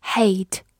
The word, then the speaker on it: hate: 最后注意对比两个近音词，第一个是 hate，h a t e，字母 a 发它本身的音 a，末尾的 e 不发音。 hate，动词、名词表示恨、仇恨。